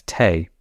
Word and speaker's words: tea: Drinking tea took off in England in the 17th century, and it was first known as or pronounced as tay. And that's because we got the word tay from the Dutch, who were major importers of tea. And they took the Malay word and pronunciation tay.